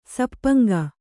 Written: ಸಪ್ಪಂಗ

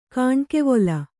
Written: ಕಣ್ಗಾವಲು

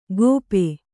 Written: ಗೋಪೆ

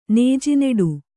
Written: ನೇಜಿನಡು

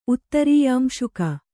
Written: ಉತ್ತರೀಯಾಂಶುಕ

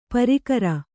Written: ಪ್ರಾಕಾರ

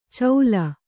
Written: ಚೌಲ

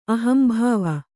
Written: ಅಹಂಭಾವ

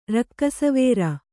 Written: ರಕ್ಕಸವೇರ

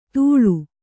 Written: ಧೂಳು

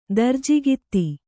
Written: ದರ್ಜಿಗಿತ್ತಿ

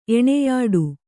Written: ಎಣೆಯಾಡು

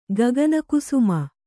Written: ಗಗನಕುಸುಮ

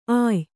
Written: ಅಯ್ಯ

